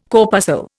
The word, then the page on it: corpuscle: Indian